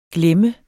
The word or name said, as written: glemme